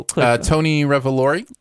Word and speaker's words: Tony Revolori: Tony Revolori.